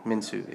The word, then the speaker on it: Minshew: If I can pronounce his name correctly. – Yeah, Minshew. – Like Minshew, yes.